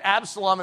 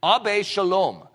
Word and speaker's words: Absalom: You know that Absalom is actually not how it's pronounced. In the Hebrew, it's Ab Shalom.